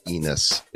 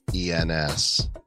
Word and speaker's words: ENS: It's not pronounced Enus, it's ENS.